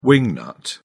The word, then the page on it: wing-nut: British English